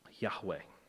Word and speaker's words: Yahweh: In Hebrew, the text provides the Hebrew consonants, YHWH, which with the verbs is often pronounced Yahweh.